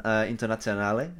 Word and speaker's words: Internazionale: Yeah, so obviously we've got Inter Milan, Internazionale, you know, the big boys.